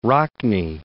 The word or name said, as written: Rockne